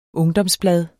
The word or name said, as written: ungdomsblad